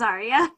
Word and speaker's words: Arya: Yes, my name is Arya.